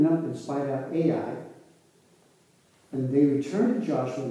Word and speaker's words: Ai: I'm going to pronounce it Ai. It's Ai.